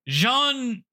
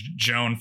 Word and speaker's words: Jeanne: Jeanne Bécu. – Okay. – Countess Du Barry. – So, Jean. – I've been saying, yeah, I've been saying Joan for Jean.